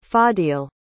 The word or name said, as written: Fadil